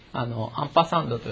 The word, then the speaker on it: アンパサンド: で、これを何と読むかというと、アンパサンドと読みます。